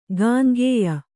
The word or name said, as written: ಗಾಂಗೇಯ